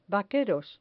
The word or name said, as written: vaqueros